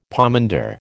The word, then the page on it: pomander: American English